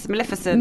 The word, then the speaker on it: Maleficent: And then after him was Zara Larsson, who, as I said, was dressed as, how do you pronounce it again? – Maleficent. – Maleficent, who she looked fucking amazing.